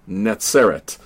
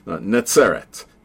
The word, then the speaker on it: Netzeret: Nazareth, or Netzeret is the way you would pronounce it today. Netzeret.